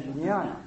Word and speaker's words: jnana: And that we distinguish that from the greater alaya, which is called alaya jnana. And that's spelled alaya, and then a second word, J-N-A-N-A.